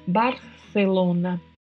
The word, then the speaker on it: Barcelona: Mas na Espanha, na maioria das regiões, o C tem uma sonoridade produzida com a língua entre os dentes. Barcelona, Barcelona.